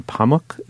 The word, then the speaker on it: Pamuk: on Orhan Pamuk. Is that how you pronounce that? – I would.